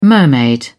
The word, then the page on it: mermaid: British English